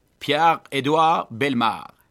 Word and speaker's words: Pierre-Édouard Belmar: Hur låter ditt franska uttal på hela namnet där? – Pierre-Édouard Belmar. – Ja, det var nog bra tyckte jag.